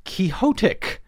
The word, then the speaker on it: quixotic: He says the term originates from Don Quixote, so the pronunciation is Quixotic.